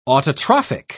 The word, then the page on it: autotrophic: British English